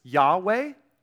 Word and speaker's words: Yahweh: And you either pronounce this Yahweh or Jehovah, depending on where the vowels go. And so Yahweh, Jehovah, you've heard both those. Those are just different pronunciations for the same word, which is this word right here, okay?